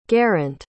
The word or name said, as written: geraint